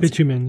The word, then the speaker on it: bitumen: So there were some things that you could find locally, but a lot of things, let's see, I don't know how to pronounce this, bitumen? I think it's pronounced bitumen, yeah. – Bitumen, okay, yeah.